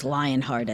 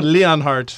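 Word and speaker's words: Leonhart: We learned yesterday that, is it Leonhardt? Is that the correct pronunciation of that name? – I thought it was Lionheart at first. – Yes, but no, Leonhardt.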